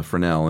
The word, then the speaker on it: Fresnel: It's spelled Fresnel, but yeah. it's pronounced Fresnel, and it's that, Coke bottle, you know, ribbed, right.